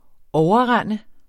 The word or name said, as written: OVERREND